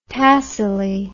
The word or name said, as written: Tassili